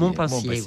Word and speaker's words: Montpensier: Nous, je le prononce bien, Montpensier. – Oui, Montpensier, oui.